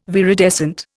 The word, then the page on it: viridescent: Indian